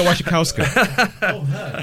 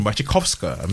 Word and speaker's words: Wasikowska: Yeah, Mia Wasikowska. Yes, you see, I've been practicing that name. It's like Wasikowska. Or maybe even Wasikowska, maybe, if you wanted to give it the original Polish pronunciation.